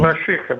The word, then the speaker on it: Балашиха: Балашиха или Балашиха? Вот сейчас скажет, как правильно говорить. Балашиха, Балашиха.